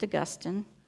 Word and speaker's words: Augustine: St. Augustine, not to be confused with the city of St. Augustine to our south, it's pronounced Augustine, has this to say.